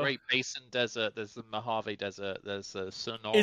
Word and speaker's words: Mojave: Mojave, see, there you go. – Mojave, yeah. – It is. – That's how you pronounce it, yeah.